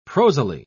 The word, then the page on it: prosily: British English